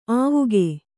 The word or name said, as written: ಆವುಗೆ